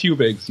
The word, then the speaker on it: Hubig's: How you pronounce it? – It's Hubig's. Yeah, he's Hubig.